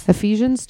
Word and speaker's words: Ephesians: Ephesians 2:1.